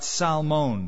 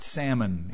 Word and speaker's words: Salmon: Nashon begot Salmon. By the way, it's not salmon, even though it's spelled like the fish.